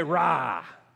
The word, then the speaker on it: Ra: Everybody say rah.